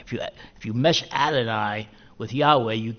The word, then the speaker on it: Yahweh: And it's only because of really recent scholarship within the last couple of hundred years that we understand that and pronounce it as, correctly as Yahweh.